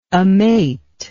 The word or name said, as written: amate